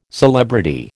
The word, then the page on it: celebrity: American English